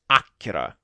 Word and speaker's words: Акира: Акира. Ну или Аккера, как его произносят в самом фильме.